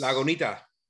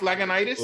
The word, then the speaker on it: Lagunitas: That's how it's pronounced. Lagunitas. – Lagunitas. – Are you serious? Real? I've been saying it wrong this whole time. – Lagunita? – Yeah, it's because you're in Miami, so you just figure, oh, eso es Lagunita. But no, it's Lagunitas.